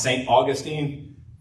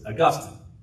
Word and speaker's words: Augustine: I know the city in Florida that's named after him is pronounced St. Augustine, but his name properly pronounced is Augustine.